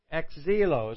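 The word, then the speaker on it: exilo (ἐξέλκω): It says exilo. Exilo is how I pronounce that.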